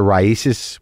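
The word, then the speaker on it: RAICES: Thank you for donating to Raisis, which is how you pronounce it, apparently.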